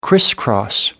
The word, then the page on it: crisscross: British English